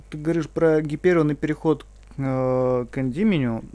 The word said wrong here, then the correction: Эндимион: Да. – Как Эн, Эндимион. – Эндимион. Эндимион, правильно, ударение, да.